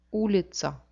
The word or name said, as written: ulitca